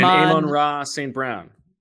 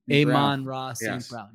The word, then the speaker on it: Amon-Ra St. Brown: Amon-Ra St. Brown. – Amon-Ra St. Brown, yes.